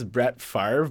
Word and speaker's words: Favre: It's funny because his name's Brett Favre, but it's spelled Favre. F-A-V-R-E.